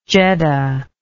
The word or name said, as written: jedda